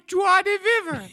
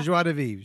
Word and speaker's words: joie de vivre: Oh, my joie de vivre. – Yeah, your joie de vivre. Your joie de vivre. Joie de vivre is how it's pronounced.